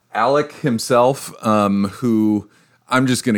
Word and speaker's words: Alex Murdaugh: Guilty or innocent, you suck because his name is spelled A-L-E-X, Alex. – Yeah. Right. – And I'm going to have to continually remind myself to say Alec because that's apparently how it's pronounced. – Yeah. I did want to take a second to issue apologies to anyone who cares. I did lead this episode last time and I mispronounced both his first and last name because it looks it looks like Alex Murdaugh, and it's pronounced Alec Murdoch.